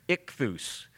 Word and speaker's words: Ichthus: Ichthus is the way you pronounce that.